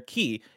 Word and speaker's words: Chi: Nano says, bless, that weird X is the Greek letter key. It's pronounced, and it's spelt C-H-I. It's pronounced key in K-H because Kingdom Hearts.